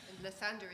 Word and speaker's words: Lathander: Lathander is spelled L-A-T-H-A-N-D-E-R. Lathander.